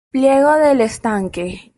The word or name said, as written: estanque